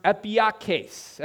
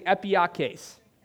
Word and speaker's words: epieikes: Now, this reasonableness is a Greek word pronounced epieikes. Everyone say epieikes.